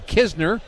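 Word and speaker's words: Knizner: Andrew Kisner, we know how to say his name, even though it's K-N-I-Z-N-E-R.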